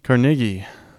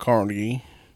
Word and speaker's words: Carnegie: Carnegie. Because it is a Scottish last name. And that is how they pronounce it in Scotland. – Well, we're in North Carolina and we call it Carnegie.